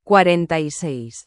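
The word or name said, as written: cuarenta y seis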